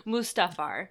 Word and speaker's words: Mustafar: Mustafar.